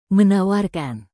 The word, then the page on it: menawarkan: Indonesian